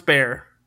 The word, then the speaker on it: Bexar: It's spelled Ursula, and then Bear is spelled B-E-X-A-R, but pronounced Bear.